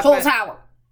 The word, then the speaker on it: Holzhauer: She already broke James Holhauer's James Hole. I don't really know how to pronounce that. Holzhauer. – Yes, thank you.